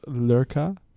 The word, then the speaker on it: Lurcat: Or like maybe there's a pretty relatively for Minnesota fancy restaurant in Minneapolis called Lurka, mm-hmm. – Or I like to say Lurcat because that's how it's spelled.